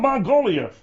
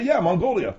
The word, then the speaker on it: Mongolia: Magnolia? – What an idiot, man. It's called Mongolia. Mongolia.